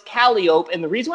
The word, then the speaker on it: Calliope: But if you ask anybody with the Superfly connections what that word is and how you say it, they'd say Calliope. – Well, we asked the guys at C3, and they said Calliope.